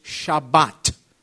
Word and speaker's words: Shabbat: So uh, in Israel, they, they, have, they do the Sephardic pronunciation. For instance, Harry, tell me if this is right. Uh, the Sephardic Jews and the Jews in Israel will say, with reference to the Sabbath, they will say Shabbat.